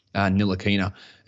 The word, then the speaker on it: Ntilikina: and they were talking about Ntilikina. It's a silent T, which I always just go to silent N, but it's a silent T.